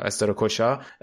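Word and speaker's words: Strakosha: و استراتوشا است... استراشوکا من همیشه اسم بنده خدا رو اشتباه تلفظ میکنم استراکوشا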